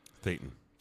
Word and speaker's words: Thetan: According to those who study Scientology materials, the receive auditing sessions advance from status of pre-clear to clear and operating thean. – Thetan. – Thetan. – I think that's how it's pronounced.